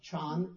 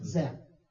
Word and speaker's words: Zen: Chan is the Chinese word for what is called Zen. More frequently what? – Zen. – Zen. The Zen is the Japanese pronunciation. So they discovered a method that the Buddha never used, called koan, K-O-A-N.